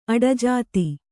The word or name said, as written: ಅಡಜಾತಿ